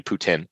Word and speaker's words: poutine: It's actually poutine in terms of the proper French-Canadian pronunciation.